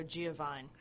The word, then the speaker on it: Giovine: Well, unfortunately, with the American pronunciation, it tends to go Giovine.